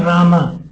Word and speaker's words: Rama: Yes, when you say Rama, don't say Ma. That's Rama Devi, Rama. Rama means goddess of fortune. Yes, it is a syllable. It's 32 syllables. It's 16 words and 32 syllables. Like Krishna, 2 syllables. Rama, 2 syllables.